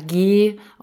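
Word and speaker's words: Ghee: Und diese Medikamente, diese Kräutermischung waren entweder mit Honig gemischt oder mit G oder G ausgesprochen, G-H-E-E -E geschrieben.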